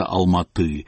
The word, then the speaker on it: Алматы: Алма-Ата должна произноситься Алматы, Белоруссия – Беларусь, Таллин требовал добавить на конце вторую «Н».